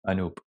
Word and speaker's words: Anoop: Anoop, yes.